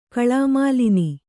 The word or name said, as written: ಕಳಾಮಾಲಿನಿ